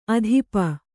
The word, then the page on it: ಆತಪ: ātapa